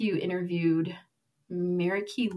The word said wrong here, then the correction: Marika: Liu. I don't know if that's how you pronounce it. Marika. Yep. – Marika. Yes.